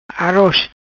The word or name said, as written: Aroz